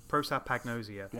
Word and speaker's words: prosopagnosia: Prosopagnosia. – Yep. – Prosopagnosia.